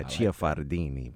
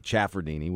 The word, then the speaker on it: Ciafardini: It's by a vowel, so it's Ciafardini, if you really feel like inspired by the Italian. Yeah, Ciafardini. But most people say Ciafardini, which is pretty good.